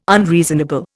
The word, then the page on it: unreasonable: Indian